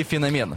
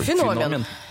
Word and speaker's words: феномен: Феномен или феномен? – Ох, ты же знаешь, как у меня проблема с ударением. – Я поэтому вас и спрашиваю. – Феномен. Ударение на «о».